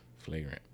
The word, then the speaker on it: flagrant: Fragrant and Flagrant. What did I say? Did I say it wrong? – You said fragrant. – Oh, flagrant. Fragrant.